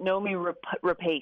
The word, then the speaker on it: Noomi Rapace: I always say it Noomi Rapace.